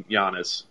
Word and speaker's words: Giannis: Look, I, I, was a I was a big, big proponent of do not wait on Giannis.